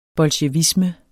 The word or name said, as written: bolsjevisme